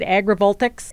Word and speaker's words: agrivoltaics: Did I say that right? Agrivoltaics? – Agrivoltaics. But yes.